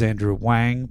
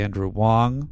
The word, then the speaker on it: Andrew Wong: You said it was Andrew Wang, but it's actually Andrew Wong, which was, this was a debate you and I had. As Blazing Bev would probably say, it rhymes with bong.